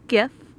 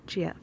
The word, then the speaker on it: GIF: Gif, although I know it's pronounced Jif.